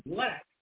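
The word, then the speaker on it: Blaq: Go to Black, however, it's spelled B-L-A-Q. B-L-A-Q, Black. Brilliance, B-R-I-L-L-A-N-C-E, on YouTube.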